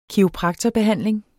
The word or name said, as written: kiropraktorbehandling